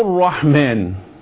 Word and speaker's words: الرحمن: الرحمن كل راء مشدد أو كل حرف مشدد قبله لام تحذف اللام نطقا.